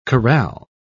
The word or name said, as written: corral